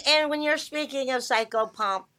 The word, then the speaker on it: psychopomp: You gotta say psychopomp. – No, yeah, that's psychopomp.